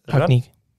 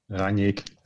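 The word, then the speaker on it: Rangnick: Hoe spreek je zijn naam uit? – Ragnik. Ragnik? – Ragnik.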